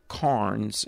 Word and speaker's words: Cairns: called Christianity Through the Centuries by a guy named Earl Carnes, and it's spelled C-A-I-R-N-S.